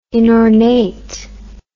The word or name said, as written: inornate